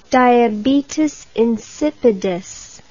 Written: diabetes insipidus

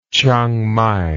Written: Chiang Mai

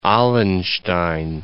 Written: Allenstein

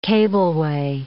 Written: cableway

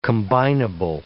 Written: combinable

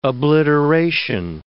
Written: obliteration

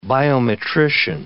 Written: biometrician

